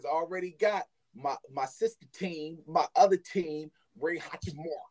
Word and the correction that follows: Rui Hachimura: Rui Hachimura.